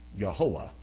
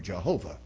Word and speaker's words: Jehovah: The reason they do is they say the Masoretes, when they took those four Hebrew consonants, they added the vowel points where you pronounce Adonai. And they took the vowel points of Adonai and they put them on Yod, Hey, Wow, Hey, and out came Yehoah or Jehovah.